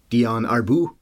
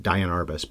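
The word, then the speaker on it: Diane Arbus: So Shainberg, the director, has only made a few other movies, like you said, Fur, which is the Dion Arbus. That's how you're supposed to say her name, apparently, not Dion Arbus, but most people say Dion Arbus.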